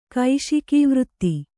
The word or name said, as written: ಕೈಶಿಕೀವೃತ್ತಿ